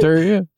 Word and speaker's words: pterodactyl: Like, have you ever heard of pterodactyl? You ever heard of pterodactyl? – No, it's pee? Silent, dude. – Yeah, it's pee silent. You can't hear a fucking pterodactyl piss. The pee is silent.